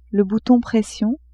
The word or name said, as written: bouton pression